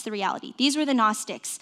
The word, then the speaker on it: Gnostics: There was a group of people called the Gnostics, silent G, kind of complicated, already kind of tells you how pretentious they were, the Gnostics.